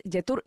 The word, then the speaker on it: Jetour: Así es que bueno, ya lo sabes, es Yetur. Yetur se escribe J-E. T-O-U-R-S, Yetur, J-E-T-O-U-R. Ahí lo vas a encontrar.